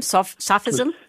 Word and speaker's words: sophism: Is it sophism? Sophism? – Sophism, yes. So yes.